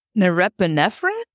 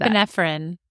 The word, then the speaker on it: norepinephrine: Biologically speaking, when you're looking at porn, norepinephrine? I probably butchered that. Norepinephrine. – Thank you.